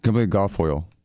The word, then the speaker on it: Kimberly Guilfoyle: Because I was going to get that, was it Kimberly Goit? How do you say her name? – Kimberly Guilfoyle.